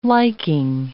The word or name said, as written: liking